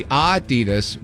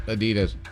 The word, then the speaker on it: Adidas: So if you really wanted to read it incorrectly, as us Americans do, it's supposed to be Adidas with a slight emphasis on the first syllable. Not Adidas.